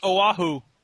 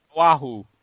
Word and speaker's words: Oahu: It's pronounced Oahu. – Wahoo. There you go.